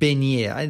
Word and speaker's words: beignet: So in Africa, there's a thing called beignet. That's how they say it in French.